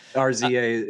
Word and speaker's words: RZA: RZA is the RZA. Sorry to correct you. – Oh, he is. It is pronounced that. I thought you were just making a classic Dan Eden. It's RZA? – No, he's RZA. Yeah. – Sweet, sweet, naive Micah. I see three capital letters and I just assume that they are pronounced separately.